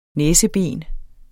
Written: næseben

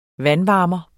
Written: vandvarmer

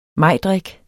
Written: majdrik

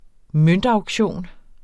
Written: møntauktion

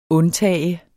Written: undtage

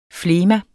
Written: flegma

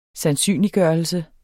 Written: sandsynliggørelse

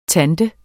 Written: tante

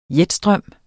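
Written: JETSTRØM

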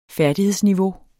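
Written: færdighedsniveau

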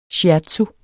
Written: shiatsu

